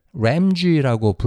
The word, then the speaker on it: RAMG: 그래서 얘를 램쥐라고 부르기로 했어요. R A M G 램쥐 한국어 단어 다람쥐에서 앞 글자를 뺀 거죠. 근데 발음은 영어식.